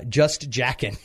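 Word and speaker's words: Jaeckin: Just Jackin.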